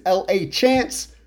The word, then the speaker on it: LaChance: My last name is L.A. Chance, pronounced LaChance.